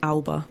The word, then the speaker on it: Alba: and we kept saying Alba, but yeah. they've told us the correct Scottish pronunciation is Alba.